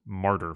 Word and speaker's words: martyr: You know, he's not given the most interesting material to work with, and his role is basically that of martyr for the second two books, especially. – Pronounced Mordor. No, wait. – Sorry. – Martyr. – Mordor.